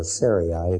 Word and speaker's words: Sarai: In verse 15 of this chapter, Genesis 17, God said to Abraham, as for Sarai, your wife, you are no longer to call her Sarai, or Sarai, it can be pronounced either way.